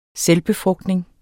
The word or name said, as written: selvbefrugtning